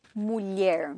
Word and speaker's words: mulher: Mulher. – Mulher. And that's spelled M-U-L-H-E-R. And so it's just from that Spanish mujer.